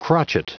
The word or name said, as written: crotchet